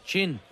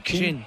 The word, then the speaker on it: Qing: Q-I-N. – Chin. – Chin is a queen. – Chin.